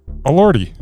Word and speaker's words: Elordi: Okay, how would you pronounce Elordi? – Elordi. But yeah, I wouldn't sit there and stutter. Elordi. Just Elordi.